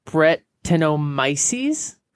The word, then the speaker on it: Brettanomyces: Brett beer is beer that has Brettomyces. I can never really pronounce it. Brettanomyces.